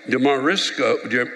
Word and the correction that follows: DeMarsico: DeMarsico. I'm sorry if I'm pronouncing this wrong. What? DeMarsico, very good.